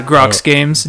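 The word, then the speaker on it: Groks Games: Yeah, Grox Games. G-R-O-K-S.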